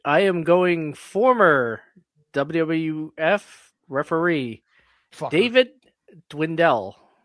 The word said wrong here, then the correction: Dwinnell: Yeah, it's Dwinell. – Dwinell. Yep. – How do you spell that? – D-W-I-N-N-E-L-L.